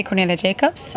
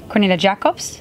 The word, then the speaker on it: Cornelia Jacobs: But I usually say Cornelia Jacobs. – Okay. – But I've always also heard Cornelia Jacobs. – Oh. – And I like that too. – It's so international, the mispronunciation.